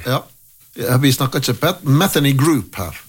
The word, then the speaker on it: Metheny: Pat Metheny. Och, eller är det sån en uttale? – Metheny, vill jag sagt.